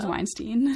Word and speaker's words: Weinstein: His name is Weinstein. – Yes. – Harvey Weinstein.